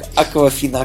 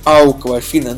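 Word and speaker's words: Awkwafina: Ин- интересно то, что она на самом деле должна произноситься не, не как «Аквафина», а как «Ауквафина», ну, типа, потому что она пишется как «AWK». Ауква.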